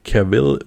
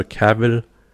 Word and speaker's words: Cavill: Ja, und das ist der Henry Cavill, spricht man aus, oder Cavill.